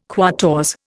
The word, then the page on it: quatorze: Indian